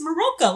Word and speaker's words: Marilka: I know, I had to write down how to spell it because in the beginning she's like, it's Marilka, like milk.